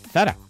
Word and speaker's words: Zara: Todo iba muy bien hasta que llegó a una marca que casualmente es española, vaya, que es Zara, y por eso se pronuncia así, Zara.